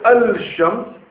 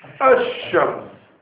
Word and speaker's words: ash-shams: So you will say, you won't say al-shams, you say ash-shams.